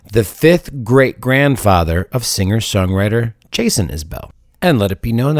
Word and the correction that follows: Isbell: that halfway through telling you that, I realized I was pronouncing Isbell incorrectly. Isbell, not Isabel.